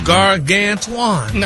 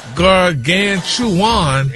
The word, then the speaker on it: gargantuan: Oh, sound like you missed a syllable there, sir. – Oh, gargantuan.